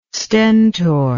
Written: stentor

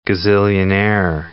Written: gazillionaire

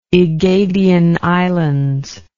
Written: aegadian islands

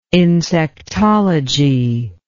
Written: insectology

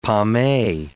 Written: pommee

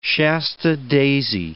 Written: Shasta daisy

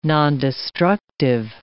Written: nondestructive

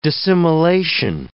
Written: dissimilation

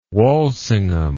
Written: Walsingham

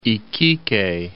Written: Iquique

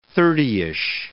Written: thirtyish

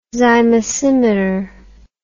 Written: zymosimeter

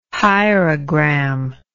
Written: hierogram

